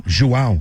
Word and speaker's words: João: J-O-A-O? – João. – There's a tilde over the A. João? Is it João? – João. – João Neto and Ken Weeks. – A lot of people will also say João, but João is how you say it in Portuguese.